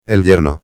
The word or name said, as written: el yerno